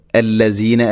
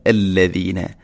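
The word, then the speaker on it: الذين: ഇന്ന അല്ലദീന, ദാലിൻ്റെ ഉച്ചാരണമാണ് ശ്രദ്ധിക്കേണ്ടത്. അല്ലസീന അല്ല അല്ലദീന, അല്ലദീന. ഇന്ന ലദീന കഫറു, ഇവിടെ കാഫിൻ്റെ ഒരു സിഫത്ത് അഥവാ ലൈറ്റായിട്ട് ഉച്ചരിക്കണം, കനപ്പിക്കാതെ.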